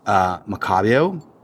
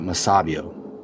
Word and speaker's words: Macabeo: No, in fact, they've got grapes that are very native to Spain, like Macabio, or is it Masabio? Macabio, probably. Macabeo.